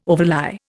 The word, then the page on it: overlie: Indian